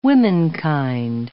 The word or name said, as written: womenkind